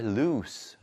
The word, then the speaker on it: loose: My shoes are loose 라고 할 수 있는 거예요. 이거는 lose가 아니고 loose 발음이 납니다.